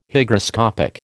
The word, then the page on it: Hygroscopic: American English